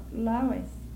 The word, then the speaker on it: loess: L-O-E-S-S? – Uh-huh. – I've never heard of that. How would you pronounce that? I'd say Lois.